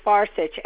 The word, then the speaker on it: Farcich: The last name is Farcich, Farcich.